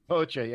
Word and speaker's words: Hoxha: Well, given it's the political DNA coming out of the paranoia of the Cold War era of this weird Enver Hoxha, is that how you pronounce it? – Hoxha, yeah.